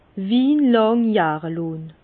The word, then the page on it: wie làng jahre lonn: Bas-Rhin; Herrlisheim